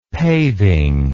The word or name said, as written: paving